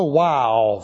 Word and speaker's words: waw: And our first file is the wow file, which a wow looks something like this. Has a little hook up there. And it's spelled W-A-W. Wow. Not W-O-W.